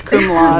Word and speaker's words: cum laude: What does it mean to what does it mean if you graduate cum laude? I really hope I pronounced that right. – It's cum laude. – Cum laude. Thank you.